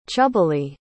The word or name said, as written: chubbily